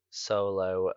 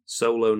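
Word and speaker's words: Xolo: You was close with the name. – How would you say it? – Solo Mariduena. – Mariduena. – Solo. – Solo, not Zolo. – X is an S at the start.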